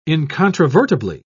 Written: incontrovertibly

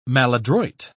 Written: maladroit

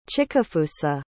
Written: chikafusa

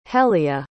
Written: helia

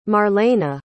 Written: marlena

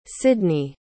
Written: cydnee

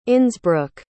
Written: innsbruck

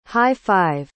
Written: high-five

